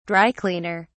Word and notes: dry-cleaner: British English

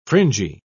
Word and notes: fringy: British English

